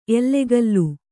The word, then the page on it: ಎಲ್ಲಾಗಲೂ: ellagalū